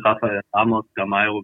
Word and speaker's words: Rafael Ramos Gameiro: Ja, ich, also ich würde ihn eigentlich immer auf äh, in der deutschen Aussprache nehmen, weil das auf Portugiesisch schon abenteuerlich wird. – Okay. – Also mit Rafael Ramos Gameiro bin ich absolut glücklich.